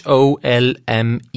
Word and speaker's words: HOLMES: I pezzi d'esposizione disposti in basso si riferiscono al computer della Polizia Metropolitana di Londra, denominato Home Office Large Major Inquiry System, abbreviato HOLMES, che naturalmente si pronuncia Holmes.